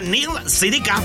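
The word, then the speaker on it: Neil Sedaka: bueno, originalmente el que canta esa canción y el que la escribió se llama Neil, se apellida Sedeca, se pronuncia Neil Sedeca.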